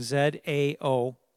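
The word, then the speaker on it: Zao (ζάω): So yeah, this Greek spelling Z A O pronounced Zao it is a verb to live, to breathe, uh, to have life.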